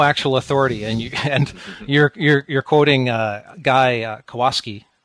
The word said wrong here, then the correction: Kawasaki: Kawasaki, thank you.